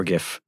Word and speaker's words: GIF: GIF or GIF, depending on how you prefer to pronounce it.